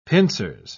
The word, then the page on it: pincers: British English